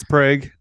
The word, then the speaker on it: Prague: And he's from Prague. I think that's how that pr- is pronounced in Oklahoma terms. – Yep, it's Prague. – Uh, yeah, so we don't um, call it Prague here in Oklahoma.